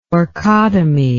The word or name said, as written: orchotomy